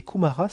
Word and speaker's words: Kumara: Sanat, S-A-N-A-T, Kumara. – Kumara, ça se prononce Kumara.